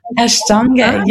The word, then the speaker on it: Ashtanga: Is it pronounced Ashtanga? – Ashtanga, yes.